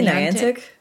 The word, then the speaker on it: Niantic: I say Niantic, but yeah.